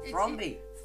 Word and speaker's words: Thrombey: Like, should she give the money back because she thought she murdered Harlan Thrombley? I can't say it right. – Thrombey. – There's no L. I think there's an L, though. – No, there isn't.